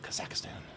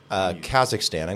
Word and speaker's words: Kazakhstan: Am I saying that right? – Kazakhstan. I think it's Kazakhstan.